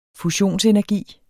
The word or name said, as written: fusionsenergi